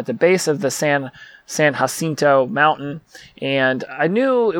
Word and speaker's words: San Jacinto: Well, it's not Jacinto. It's not San Jacinto like we would normally pronounce it. No, it's pronouncing it correctly. It's San Jacinto, like Cinco, but Cinto.